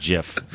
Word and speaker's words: GIF: It's actually supposed to be pronounced GIF.